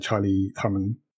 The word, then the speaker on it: Charlie Hunnam: And Jax Teller, the main character in this show, played by Charlie Hunnam.